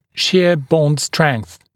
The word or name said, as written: shear bond strength